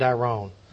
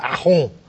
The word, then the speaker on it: Aron: The great exception was the French political philosopher, sociologist, and journalist, I'm going to shamelessly Americanize, Raymond Aron. I've written several books on Aron, and it's hard for an American to pronounce his name, Raymond Aron.